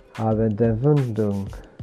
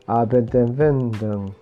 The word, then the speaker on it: Abendempfindung: Abendempfindung. Abendempfindung.